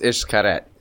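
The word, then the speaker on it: Xcaret: It's X C A R E T. – It's not I S C H or I S H. – It's pronounced Ishkaret.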